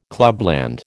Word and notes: clubland: American English

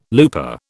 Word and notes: looper: British English

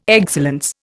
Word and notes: excellence: Indian

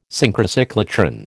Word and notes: Synchrocyclotron: American English

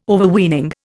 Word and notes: Overweening: Indian

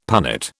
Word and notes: punnet: British English

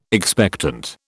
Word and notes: expectant: British English

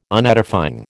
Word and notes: Unedifying: American English

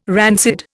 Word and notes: rancid: Indian

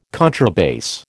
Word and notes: contrabass: American English

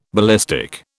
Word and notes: ballistic: British English

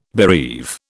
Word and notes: bereave: British English

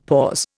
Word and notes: pause: Indian

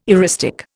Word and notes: eristic: Indian

